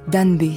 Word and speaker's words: Danbe: Un mot a guidé Aya Sissoko dans la vie, Danbe. C'est du malinké, c'est vite dit, deux syllabes et ça veut dire dignité.